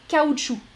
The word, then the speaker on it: caoutchouc: En français, on prononce K ou chou, mais pas le C final. Caoutchouc.